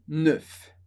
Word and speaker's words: neuf: Zero, neuf. Com um F no final.